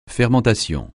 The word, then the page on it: fermentation: francais